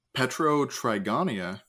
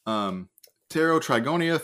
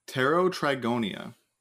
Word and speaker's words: Pterotrigonia: We have Petrotrigonia, or I guess, nope, that's a silent P. Pterotrigonia thoracica, which they named as their state fossil in 1998, which is a bivalve.